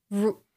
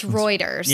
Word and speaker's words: Reuters: I didn't realize I just pronounced it wrong and I checked with a friend. So I said, Reuters. It's Reuters.